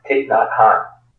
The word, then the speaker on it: Thich Nhat Hanh: Yeah, that's the Buddhist Vietnamese monk Thich Nhat Hanh.